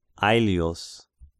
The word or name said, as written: Aelius